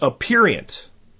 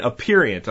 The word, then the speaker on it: aperient: And that is aperient. Again, aperient.